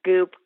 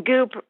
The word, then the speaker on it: Goop: Maybe the one that rhymes with moop recently? – Right. So, yeah, Tina went after Gwyneth Paltrow's Goop company.